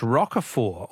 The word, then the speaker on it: Roquefort: The French Roquefort, also with a silent T, with a silent T, is very smelly.